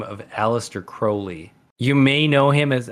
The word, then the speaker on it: Crowley: He rhymed his own name with Holy, which implies Crowley. That his last name is pronounced Crowley.